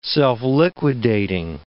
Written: self-liquidating

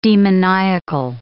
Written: demoniacal

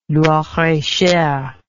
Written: Loir-et-Cher

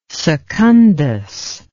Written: secundus

